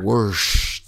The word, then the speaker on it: Worcester: Worcester. – That's it. That's Worcester. How you say it.